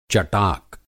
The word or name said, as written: चटाक